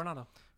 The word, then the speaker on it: Coronado: Yeah, Coronado.